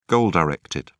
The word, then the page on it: goal-directed: British English